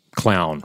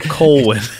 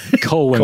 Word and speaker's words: Colwyn: It's the beast, and the beast is in the Black Fortress, and the weapon is the glaive, and our hero is Clown, right? It's not Clown, but Colwyn. – Colwyn. But it always looks on the screen. Ooh. And in text, like it's spelled almost like Clown.